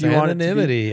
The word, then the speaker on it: anonymity: They offer the privacy and security that anonymity. Did I say that right? – Yes, anonymity, yes.